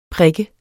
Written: prik

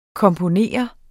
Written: komponere

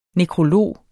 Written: nekrolog

